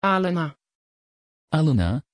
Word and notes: Alena: Dutch

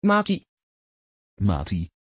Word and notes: Maty: Dutch

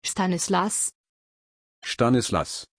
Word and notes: Stanislas: German

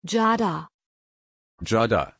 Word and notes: giada: German

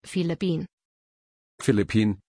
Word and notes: philippine: German

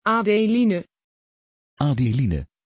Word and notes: adeline: Dutch

